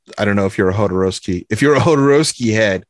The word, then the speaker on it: Jodorowsky: What is the correct pronunciation? – Alejandro Jodorowsky. – Jodorowsky. – Yeah. – Jodorowsky. – Yeah, the J is like an H.